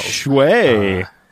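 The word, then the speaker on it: Cwej: Shway. – That's how I understand it's pronounced.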